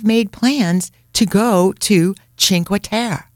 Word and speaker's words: Cinque Terre: This is how she pronounced it. Cinque Terre. – Okay. She's worse than me. – Right? Dio mio, she couldn't even pronounce it correctly. Cinque Terre. Cinque Terre is as crowded as Positano, Firenze, and Venezia.